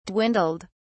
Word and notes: dwindled: British English

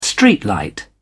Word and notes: streetlight: British English

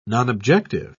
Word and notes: nonobjective: British English

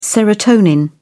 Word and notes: serotonin: British English

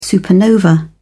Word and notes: supernova: British English

